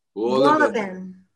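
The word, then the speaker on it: Wohlleben: Wollobin.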